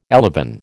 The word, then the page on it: elevon: American English